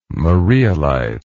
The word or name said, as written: marialite